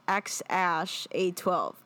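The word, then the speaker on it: X Æ A-12: And there's a video of him saying, oh, yeah, we named our kid X-Ash A-12. That's literally how you say it.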